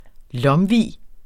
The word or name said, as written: LOMVIEN